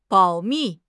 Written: 保密

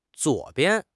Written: zuo3 bian5

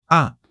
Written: a5

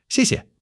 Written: xie4 xie5

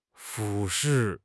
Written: 俯视

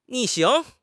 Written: 逆行

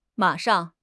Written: ma3 shang4

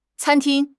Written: can1 ting1

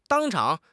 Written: 当场

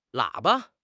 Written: 喇叭

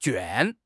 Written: 卷

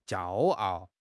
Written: jiao1 ao4

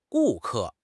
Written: gu4 ke4

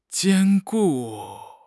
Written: 坚固

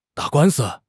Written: 打官司

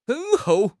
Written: 等候

